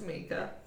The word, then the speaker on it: Micah: Spelled like Micah. – Pronounced Mika.